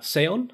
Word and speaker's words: SEON: So, Jimmy, you're the Chief Commercial Officer at SEON.